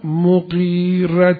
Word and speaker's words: مغیره: مغیره به غین به کسر قاف، تلفظ خیلی ها اشتباه میکنن به فتح قاف مغیره میگن، به کسر به اصطلاح به کسر غینه نه به فتح غین، مغیره نیست مغیره.